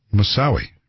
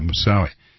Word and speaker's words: Moussaoui: Moussaoui. Right. Yeah, Moussaoui.